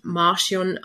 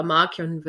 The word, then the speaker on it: Markion: Eben erst im ersten englischen Hörbuch sprechen die es tatsächlich Markion aus. Was aber sich im Lauf der Zeit dann herausgestellt hat, dass das falsch ist. Ich weiß nicht, wie es dazu kommen konnte, aber Markion wird er jetzt immer ausgesprochen.